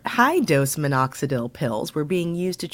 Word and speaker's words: minoxidil: So it says, I'm going to mispronounce this, minoxidil? – I think it's minoxidil, yeah. – Minoxidil, okay. The active ingredient in Rogaine,